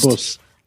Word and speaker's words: Abus: Okay, since we're here in the U.S., it's Abus, and if we want to pronounce it properly, Abus. Please do your best. – Abus.